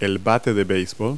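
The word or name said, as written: baseball bat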